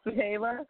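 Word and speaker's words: Zuheila: I'm just Suhaila because it's, no one else has my name.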